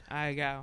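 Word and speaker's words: Ahegao: You know, it was like, is that how you pronounce it? Aha Gao? – That's how I pronounce it.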